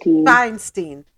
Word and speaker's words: Feinstein: Feinstein. Which is it? With a win, Lee would become the second. Go ahead. – It's Feinstein.